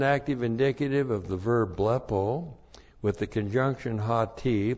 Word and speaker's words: blepo: Blepo. B-L-E-P, long O, blepo.